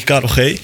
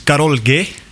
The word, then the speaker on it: Karol G: En dan denk je Karel G. Maar dat spreek je uit als Karol G.